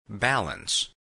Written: balance

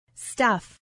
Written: stuff

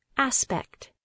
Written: aspect

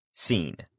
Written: scene

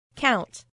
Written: count

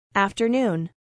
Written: afternoon

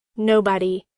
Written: nobody

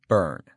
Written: burn

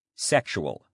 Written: sexual

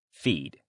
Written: feed

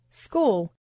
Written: school

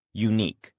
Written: unique

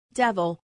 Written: devil

